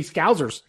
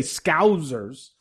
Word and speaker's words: Scousers: I wrote it out phonetically in my notes here, so I would say Scousers.